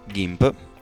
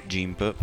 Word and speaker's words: GIMP: Allora, il software di questa settimana è GIMP, lo pronuncio all'inglese, in italiano si può pronunciare GIMP, però sarebbe scorretto.